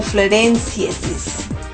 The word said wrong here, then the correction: floresiensis: Bueno, este Homo florensiensis, que lo pronuncie mal, como las flores, de hecho dice floresiensis, ¿ok?